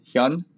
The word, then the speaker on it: Hyun: So for people who don't know, I pronounce my last name. It is Hyun. It's like Hyundai. Mm-hmm. Just take out the day.